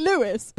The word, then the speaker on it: Lewes: I thought it was pronounced Lewis. – But it's actually Lewis. – But it's spelled L-E-W-E-S.